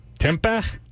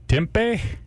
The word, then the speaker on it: tempeh: Tempeh? Tempeh? – Tempeh? – Tempeh. – Spell it. – T-E-M-P-E-H.